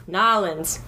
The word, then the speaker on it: New Orleans: Okay, so the proper way to say it is Nolens.